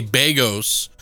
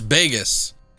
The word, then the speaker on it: Begos: And I'm always trying to say his name right, because everybody wants to say Bagos. – Right. – But it's Bagos. So, like, Vegas, but Bagos. It's just B-E-G-O-S.